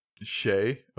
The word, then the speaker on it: Shai: Is spelled S-H-A-I. – Shay, okay.